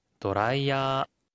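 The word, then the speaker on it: 도라이야 (ドライヤー): 하이 도라이야라고 발음할 때 끝에 발음에 주의가 필요한데요. 어가 아니라 야로 발음하고요. 자음으로 길게 끌어서 읽어야 합니다.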